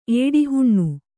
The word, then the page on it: ಏಡಿಹುಣ್ಣು: ēḍihuṇṇu